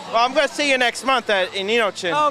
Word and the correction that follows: Inochikon: Am I saying that right? – It's Inochikon.